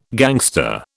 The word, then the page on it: gangster: British English